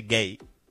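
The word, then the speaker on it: Guéhi: se escribe Guehi, pero creo que se dice Gay.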